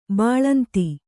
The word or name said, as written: ಬಾಲೇಂದು